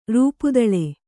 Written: ರೂಪುದಳೆ

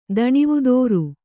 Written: ದಣಿವುದೋರು

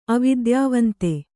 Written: ಅವಿದ್ಯಾವಂತೆ

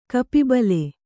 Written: ಕಪಿಬಲೆ

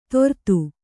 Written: ತೊರ್ತು

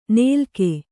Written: ನೇಲ್ಕೆ

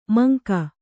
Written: ಮಂಕ

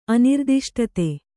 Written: ಅನಿರ್ದಿಷ್ಟತೆ

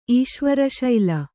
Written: ಈಶ್ವರಶೈಲ

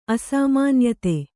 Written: ಅಸಾಮಾನ್ಯತೆ

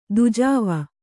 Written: ದೂಜಾವ

